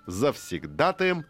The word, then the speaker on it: завсегдатаем: Чизкейк обрел совершенно неповторимый лоск, нежную, напоминающую суфле структуру, разнообразил себя дополнительными компонентами, топпингами, да, это называется на языке кулинара, и стал завсегдатаем множества современных гламурных кафе и ресторанов. Именно завсегдатаем. Ударение здесь. Все верно. На этот слог.